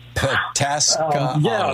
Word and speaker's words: Pataskala: Pataskala.